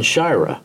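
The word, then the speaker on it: Sciarra: And I said, how would you like your name pronounced? And he said, John Shira. And from that point on, he was always John Shira with me, but Dick would still refer to him, and a lot of people would call him John Shira.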